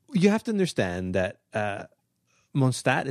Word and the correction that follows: Monast: Monast. – Monast.